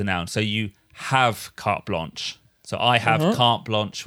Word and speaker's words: carte blanche: But again, no need to make it sound French. Carte blanche.